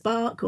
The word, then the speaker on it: Bach: So for this, these bark, or there's many different ways people pronounce it, spelled B-A-C-H, flower remedies.